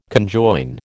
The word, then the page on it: conjoin: American English